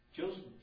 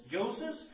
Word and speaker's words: Joseph: Is not his mother, who's called Mary, and his brother and James, and how do you say that, Josie? – Joseph. – Joseph.